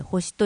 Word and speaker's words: 地球: 6 月 2 日日曜日から 8 日土曜日に、次世代へつなごう無事故と青い星、地球と書いて星と読みますを合言葉に、危険物安全週間を実施します。